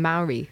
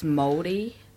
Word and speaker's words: Māori: That's how it's spelled, but it's Māori. – Okay. – Kind of like if you talk to a Māori person, they'll be like, it's like moldy, like moldy cheese.